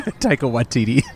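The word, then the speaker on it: Taika Waititi: Number 9 is an untitled Takaki. – Oh, Taika. – Taika. Watati. How do you say? – Taika Watiti. – Oh, yeah. So close.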